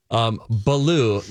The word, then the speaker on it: Baloo: Baloo, not blue.